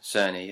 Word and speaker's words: Cerny: Mark Cerny. Isn't it? – Cerny, yeah.